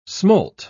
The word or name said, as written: smolt